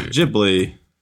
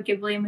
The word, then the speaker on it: Ghibli: I think it's going to be pronounced Ghibli. There's an H in there.